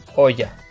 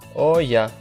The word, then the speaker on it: olla: Оя. Оя. Это будет кастрюля. Оя. Пишется через двойную L тоже. О, двойная L, А. Оя. Читается как И.